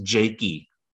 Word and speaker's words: Jicha: Andrew Jakey, I finally learned how to pronounce his last name. J-I-C-H-A. It's pronounced Jakey.